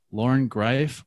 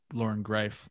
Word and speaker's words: Greiff: Loren Greiff rhymes with life.